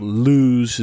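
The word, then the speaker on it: Lewes: Lose is the right way to pronounce that.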